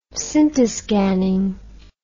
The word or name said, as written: scintiscanning